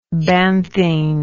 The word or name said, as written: banthine